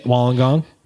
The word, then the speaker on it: Wollongong: But now we know how to say it, Wollongong.